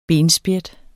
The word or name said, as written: benspjæt